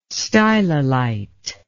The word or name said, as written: stylolite